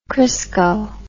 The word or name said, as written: Crisco